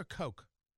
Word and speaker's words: Koch: Is it Cook or Coke? But I think it's Jim Cook, isn't it? Yeah, I think it's Cook. K-O-C-H is how it's spelled.